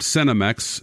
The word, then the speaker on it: Senomyx: They've unearthed this information about Cenomex. By the way, that's spelled S-E-N-O-M-Y-X, and Pepsi, as they've combed through the company's patents.